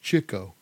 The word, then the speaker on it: Chico: Well, actually, it's pronounced Chico because he was always after the chicks.